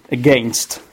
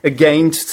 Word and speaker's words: against: Be careful, the stress is on the second syllable if you talk about the pronunciation, against. So it starts with the schwa sound, and then you have gainst, against, all right?